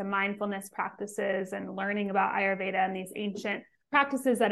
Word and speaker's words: Ayurveda: So how did you transition from that to the wellness space and get into Ayurveda? Am I pronouncing it right? I realize. – Yes. Yep. You're pronouncing it right. Ayurveda.